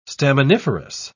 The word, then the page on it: staminiferous: British English